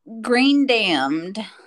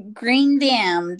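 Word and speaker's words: Greendamn: Green damned. Oh, uh, okay, wait. Spell that one for me again. Green damned. That's like the color green. Uh huh. And like damn.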